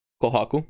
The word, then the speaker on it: Kohaku: Kohaku.